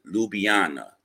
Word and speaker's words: Ljubljana: When you see it, guys, it's spelled Ljublana, but you pronounce it Ljubljana.